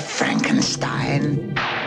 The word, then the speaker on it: Frankenstein: Dr. Frankenstein. – Frankenstein. – You're putting me on. – No, it's pronounced Frankenstein.